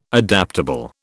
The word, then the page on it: adaptable: British English